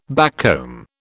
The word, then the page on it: backcomb: British English